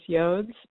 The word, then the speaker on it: yods: We're going through kind of a period of time where we're having yods, Y-O-D-S, or maybe it's pronounced yodes, but I always say yods.